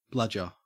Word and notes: bludger: Australian English

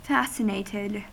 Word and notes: fascinated: American English

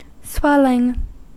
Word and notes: swelling: American English

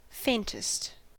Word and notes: faintest: American English

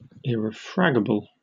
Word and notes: irrefragable: British English

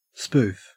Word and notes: spoof: Australian English

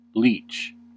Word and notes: leech: American English

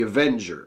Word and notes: avenger: American English

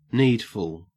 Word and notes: needful: Australian English

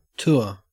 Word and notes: tour: Australian English

